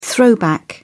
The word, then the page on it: throwback: British English